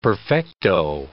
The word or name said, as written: perfecto